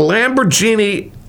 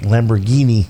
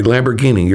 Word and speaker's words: Lamborghini: The Lamborghini Aventador SVJ. – Lamborghini. – Oh, I'm sorry. Lamborghini. You're right.